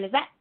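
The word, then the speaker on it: Lisette: So on Twitter and Instagram, I'm just at Cara Lizette, all one word.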